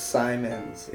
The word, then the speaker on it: Simons: Check me out on Twitter, my full name, Benjamin Simons. Last name is spelled S-I-M-O-N-S, not Simmons. – It's Simons.